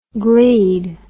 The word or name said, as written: glede